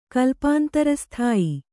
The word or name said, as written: ಕಲ್ಪಾಂತರಸ್ಥಾಯಿ